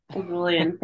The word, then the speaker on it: Erulian: Erulian, thank you.